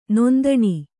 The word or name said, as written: ನೊಂದಣಿ